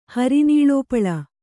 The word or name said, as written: ಹರಿನೀಲೋಪಲ